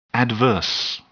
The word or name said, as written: adverse